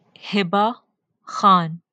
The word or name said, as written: Hibah Khan